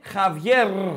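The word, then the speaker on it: Javier: Προπονητή Χαβιέρ, Χαβιέρ είναι. Κάτι αυτή που λέτε Χαβιέ και γράφετε Χαβιέ είναι Χαβιέρ. Έχει ρο στο τέλο, το οποίο διαβάζεται στα Ισπανικά.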